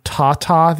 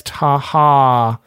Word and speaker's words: Taha: It's like for some reason, whenever I saw it, my eyes just saw Tata the Automator. And he hit me up on the side. He's like, hey, I've been meaning to tell you, you've been saying it wrong. It's Taha, the Automator.